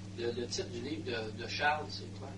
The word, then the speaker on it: Charles: Ça s'écrit Charles en anglais, on doit prononcer Charles, non ? – Charles. – Charles. Alors, ça s'écrit Charles, comme le prénom Charles en français.